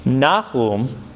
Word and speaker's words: Nahum: And actually, Nahum rhymes with this word compassion, rahum, which is interesting.